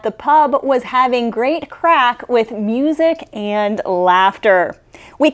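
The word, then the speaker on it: craic: Yes, that is pronounced crack, even though it's C-R-A-I-C. Because in Ireland, they don't just speak English, but a kind of language called Irish as well.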